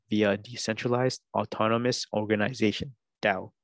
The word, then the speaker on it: Tao: Tao means path and is pronounced Dao.